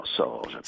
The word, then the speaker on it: Sorge: Mais non, mais ça ne saute pas à l'esprit parce que ça ne s'écrit pas comme Georges à l'envers, mais ça se prononce comme Georges à l'envers. – Parce qu'il y a un J là-dedans, Sorge.